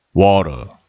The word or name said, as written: water